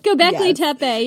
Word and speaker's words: Göbekli Tepe: You did the episode where we pronounced the name of the site wrong the entire time. – Göbekli Tepe. – Is that right? – Yeah. It's Göbekli Tepe.